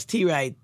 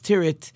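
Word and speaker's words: Tyrwhitt: There's a company in London called, I always pronounce it Charles T. Wright. I think people pronounce it Charles Tirrett.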